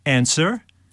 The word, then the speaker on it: answer: Or answer. – P. W. – W yeah.